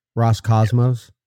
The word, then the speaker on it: Roscosmos: Ros Roscosmos.